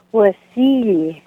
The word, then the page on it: wasili: Ninilchik Russian